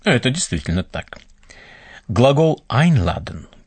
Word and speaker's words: einladen: Бывают приставки, которые придают глаголу иную специфику или даже полностью меняют его значение – Послушайте еще раз глагол «einladen» – «приглашать». Обратите внимание на приставку «ein». На нее падает ударение, потому что значение ее велико. Einladen.